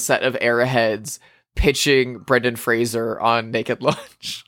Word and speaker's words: Fraser: Same thing with Brendan Fraser. Everyone pronounced it like the Kelsey Grammer show from the 90s his entire life, and it took until the Whale campaign for him to be like, guys, it's Fraser.